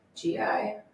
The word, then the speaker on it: Gillette: And for those that always get confused, our name is spelled like the razor. Gillette. – Very simple. – It is. But. – It's French. Okay, perfect.